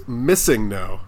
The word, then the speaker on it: Missingno: It's Missingno. As in missing number.